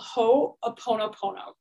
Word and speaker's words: Ho'oponopono: So the first thing that I want you to do, okay, this is a prayer called Ho'oponopono. You can look it up. It's spelled exactly the way that it sounds, H-O-O-P-O, and then Google will fill in the rest.